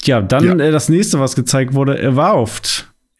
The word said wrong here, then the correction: Avowed: Avowed, ja. avowed, ich kann es nicht aussprechen. – Wie, avowed. Wie wow, avowed.